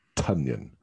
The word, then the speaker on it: Tonyan: But on the other side, tight end, Robert Tonyan, spelt like Tonyan, pronounced like Funyan.